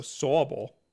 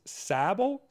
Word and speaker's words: Sobble: Trevor Grover said Sobble is pronounced with an aw, so Sawble.